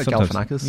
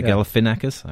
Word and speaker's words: Galifianakis: So the first two episodes I've seen of the new season are Zach Galifianakis and am I saying his name right? – Yeah, Galifianakis. – Yeah, Galifianakis. I think I put an R in there.